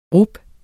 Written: rub

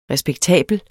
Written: RESPEKTABLE

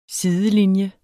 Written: sidelinje

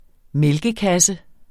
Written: mælkekasse

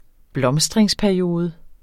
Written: blomstringsperiode

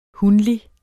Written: hunlig

